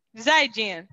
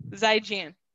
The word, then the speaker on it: Zaijian: Zaijin. Zaijian. Zaijian is how you say it in Mandarin.